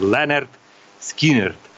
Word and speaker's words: Lynyrd Skynyrd: Кстати, вот многие вот неправильно называют эту группу. Называют Leonard Skinner, как пишется. Называют Lynyrd Skynyrd. А я еще с школьной скамьи 1974 года слушал «Голос Америки». Я знаю, что она правильно называется Leonard Skinner.